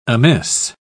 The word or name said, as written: amiss